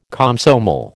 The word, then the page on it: Komsomol: American English